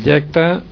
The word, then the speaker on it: jacta: Quiero hacer una observación sobre el jacta, El yacta en español evoluciona en echada, efectivamente.